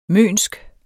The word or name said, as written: mønsk